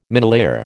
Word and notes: minelayer: American English

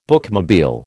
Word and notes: bookmobile: American English

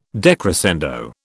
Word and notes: decrescendo: British English

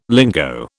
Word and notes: lingo: British English

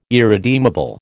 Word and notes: irredeemable: American English

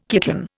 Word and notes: kitten: Indian

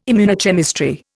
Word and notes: immunochemistry: Indian

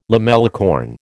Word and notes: lamellicorn: American English

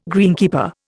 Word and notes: greenkeeper: Indian

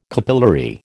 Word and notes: capillary: American English

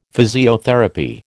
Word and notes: physiotherapy: American English

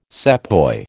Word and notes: sepoy: American English

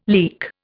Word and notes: leek: Indian

